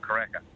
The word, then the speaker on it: Karaka: Well, if you're from the North Island, you normally say Karaka. If you're from the South Island, you call it Karaka.